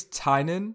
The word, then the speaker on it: Tynan: I think it's Tynan. Tynan, T-Y-N-A-N.